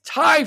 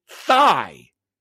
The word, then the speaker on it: Thai: So former classmate of DeSantis, who shared that the future governor would tell his dates that he liked Thai food, but would pronounce it thigh.